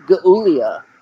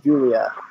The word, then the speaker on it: Giulia: I'm, I'm really surprised we didn't get a Gaulia. – What the You need to do, uh... – G-I-U-L-I-A. That's pronounced Julia.